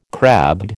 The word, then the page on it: crabbed: American English